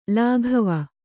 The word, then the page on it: ಲಗಾವು: lagāvu